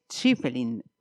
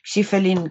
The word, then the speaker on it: Schieffelin: Le voy a preguntar a esa autora si lo primero lo acabo de pronunciar bien. Karen Santos, bienvenida y gracias por este tiempo, antes que nada. – Muchas gracias y gracias a ti. Sí, lo has pronunciado bien, aunque yo creo que, lo has hecho un poco difícil. Schiffelin creo que sería más fácil.